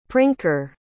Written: prinker

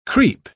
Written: creep

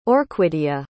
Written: orquidia

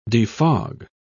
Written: defog

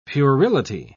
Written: puerility

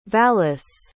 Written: vallis

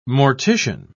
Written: mortician

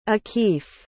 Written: akif

